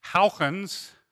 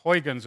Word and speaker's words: Huygens: It was solved by the Dutch physicist Huygens, or Huygens as we tend to pronounce it in English, and this was a related problem, the tautochrone problem, which was how to design a pendulum.